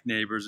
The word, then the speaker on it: Nabors: My name is Mike Nabors, and it's spelled N A B O R S.